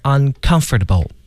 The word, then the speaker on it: uncomfortable: 对，这个重音呢大家看是在那个 c 啊，这个字母 c 上，但是 c 呢读成 k 这个音，所以就是 uncomfortable。